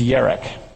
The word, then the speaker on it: yerek: You know, the normal Hebrew term for vegetable, and I may not pronounce it correctly, is yerek. In English, it'd be Y-E-R-E-K.